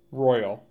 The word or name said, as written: Royal